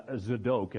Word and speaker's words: Zadok: Zadok is how you actually pronounce that.